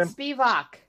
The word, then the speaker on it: Spivak: I think Lewis is still going to win because I think the guys at Spivak, how do you pronounce his name? – Spivak. – Spivak.